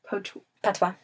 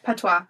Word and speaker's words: patois: Yeah, they say it's, which I'm probably pronouncing this wrong, Jamaican Patois. – Patois, yeah. Mm-hmm.